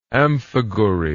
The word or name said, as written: amphigouri